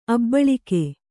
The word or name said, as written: ಅಭಿಲೇಖ